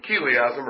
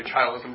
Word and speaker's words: chiliasm: Okay, premillennialism, or chiliasm, or chilism, depending on how you're talking. So let's say chilism, because I think that's the kind of common pronunciation, the common mispronunciation.